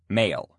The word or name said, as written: male